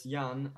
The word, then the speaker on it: Jan: So is it Jan, first of all? – Yeah, it's Jan, and then it's Ryczkowski.